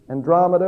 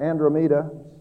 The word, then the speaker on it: Andromeda: It would take me 2 million more years to arrive at the nearest galaxy, Andromeda or Andromeda. Some have said it's pronounced either way.